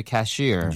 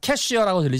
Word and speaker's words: cashier: Pay the cashier. – Yes. You have to get in line to pay the cashier. – 맞습니다. 발음 주의하세요. 캐시어라고 들리지 않고요. Cashier.